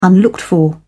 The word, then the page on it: unlooked-for: British English